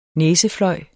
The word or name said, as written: næsefløj